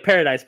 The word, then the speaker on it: Paradis: Her name is Vanessa Paradis, which her last name is kind of interesting, but it's spelled like paradise, but it's pronounced like Paradis because it's French.